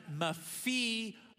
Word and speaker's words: Mephibosheth: And you pronounce it Mephibosheth.